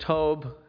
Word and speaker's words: tob: It's the word tob, pronounced in Hebrew.